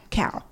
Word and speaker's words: Kao: So you have been a researcher or a scientist at KAO, or is it Cal? – Cal. – Cal.